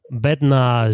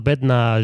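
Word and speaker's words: Bednář: Also ich glaube, dass Bednar mittlerweile wahrscheinlich so ausgesprochen wird, aber kurz noch, Jan Bednar ist wahrscheinlich die richtige Aussprache, weil der hat noch so ein A-Hackel drauf und dann noch ein R-Hackel drauf. Ich weiß nicht genau, wie die Akzente heißen. Also Bednar, langes A und dann J, wie Jan Kovac.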